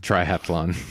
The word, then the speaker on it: triathlon: and learn how to pronounce triathlon. – Triathlon. Yes, that was the most important thing. – Yeah. Take out that extra A.